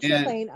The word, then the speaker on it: Champlain: Yeah, Lake Champlain.